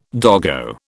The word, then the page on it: doggo: British English